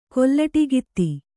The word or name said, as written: ಕೊಲ್ಲಟಿಗಿತ್ತಿ